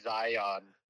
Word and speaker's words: Zion: Well, said it incorrectly, but that's, you know, no, that's great. – Zion's. – I think there's just one. – Zion's? Yeah, it's just Zion. It's the pluralization. Just Zion National Park.